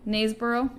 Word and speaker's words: Knaresborough: Nate, like you drop the K. – Knaresborough.